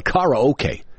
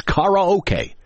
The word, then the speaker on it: karaoke: In Japan, they call it karaoke. That's the official pronunciation. Here in America, we don't know that. But it's karaoke, and we call it karaoke.